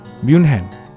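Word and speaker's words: München: 뮌헨 우리가 뮌헨이라고 하죠. 미국에서는 뮤니크 뮤닉이라고 얘기하고 독일에서는 뮌헨, 뮌헨 이렇게 얘기해요.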